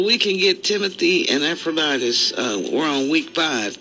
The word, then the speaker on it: Epaphroditus: Is this Epaphroditus? Epaphroditus? Is that right? – Yeah, that's pretty good. Epaphroditus. I think you did fine.